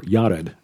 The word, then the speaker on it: Jared: He fathered Jared, or Yared, I guess would be a closer Hebrew pronunciation.